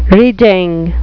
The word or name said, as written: reeding